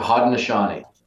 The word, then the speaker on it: Haudenosaunee: Haudenosaunee. – Yeah, Haudenosaunee.